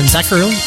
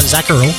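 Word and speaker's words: Zacherle: Heard John Zacherl. That's how you say it. John Zacherl.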